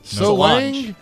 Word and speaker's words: Solange: Beyonce's sister, Solange. Is that how you say her name? Solange? – No. Solange, Adam Finkin says.